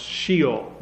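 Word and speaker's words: Sheol (שְׁאוֹל): It's a word that would be pronounced Sheol.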